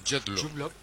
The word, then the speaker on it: Jude Law: No deja de mostrarte a Jude Law, ¿cómo se pronuncia Jude Law? – Jude Law.